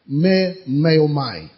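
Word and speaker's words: memeomai: Now, he uses a word here, me-me-o-mai, in the Greek. I'll spell it for you. M-E-M-E-O-M-A-I. M-E-M-E-O-M-A-I.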